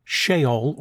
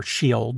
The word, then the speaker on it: Sheol: In the older texts of the Old Testament, the place of the dead was called Sheol, or Sheol, depending on how you want to pronounce that word.